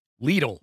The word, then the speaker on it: Lidl: Lidl rhymes with needle. – Our apologies to Lidl. Alan Cumming, who was involved in this project as a celebrity, one of the judges, described Mr. McDonald's audition as firm, but sort of cheeky at the same time. – Oh, that's how I'd describe Alan. – Firm and cheeky. – How did you say it? – Lidl. – Lidl, like needle.